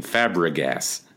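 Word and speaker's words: Fàbregas: You really you stumbled over that name. That was Sorry, Cesc. I really butchered your name. Fabregas.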